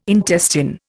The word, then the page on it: intestine: Indian